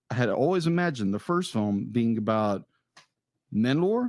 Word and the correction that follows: Manolo: Am I saying that right? – Manolo."